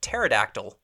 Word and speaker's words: pterodactyl: Like a laser beam is spelled like L-A-S-E-R. Like that, okay. That should 100% be a Z, definitely. – Yep. – And then we get into the silent letters, which bother me sometimes. Sometimes it's okay. It makes a little bit of sense. But pterodactyl is awful. – Yeah, pterodactyl's got the P in front of it.